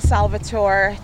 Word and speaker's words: Salvatore: Hi guys, my name is Tiba Salvatore, T-I-B-A, Salvatore, S-A-L-V-A-T-O-R-E.